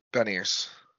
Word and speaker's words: Beniers: Beniers.